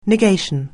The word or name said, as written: negation